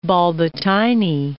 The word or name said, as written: Bolbitine